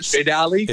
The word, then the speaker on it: Stradale: is that how you say it? Stradale? – It's Stradale.